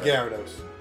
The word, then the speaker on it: Gyarados: It's pronounced Gyarados.